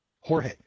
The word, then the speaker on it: Jorge: that name in Spanish is Jorge. Jorge. And I thought, well, that's kind of strange. And it's spelled G-E-O-R-G in Spanish.